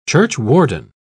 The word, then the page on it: churchwarden: British English